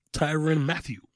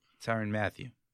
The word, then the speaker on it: Tyrann Mathieu: Wright eight, former Jet Sheldon Richardson is number nine, and the honey badger, Tyron Mathal. Right? Am I saying his name right? Tyrann Mathieu. Tyrann Mathieu. Thank you.